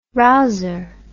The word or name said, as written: rouser